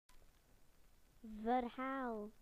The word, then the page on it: story: British English